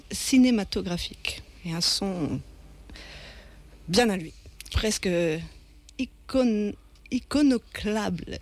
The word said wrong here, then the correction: iconoclaste: C'est iconoclaste. Iconoclaste, ouais, ouais, je vais y arriver.